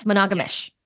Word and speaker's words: monogamish: Am I saying that right? – Yes, monogamish.